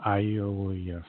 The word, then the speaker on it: Iulius: So before Christianity came along, Julius or Iulius Caesar, if you want to pronounce his name, how it was originally, was called God, made manifest, and the savior of human life.